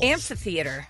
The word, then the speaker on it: amphitheater: Amphitheater. – You're dropping syllables. – Amphitheater.